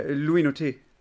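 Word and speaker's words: Loïc Nottet: Dann gab es, den kennst du wahrscheinlich auch noch, Loic Netet, wird das ausgesprochen? – Ja, Louis Noté. Louis Noté, perfekt.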